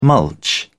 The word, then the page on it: mulch: British English